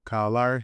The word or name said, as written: Kyle Lowry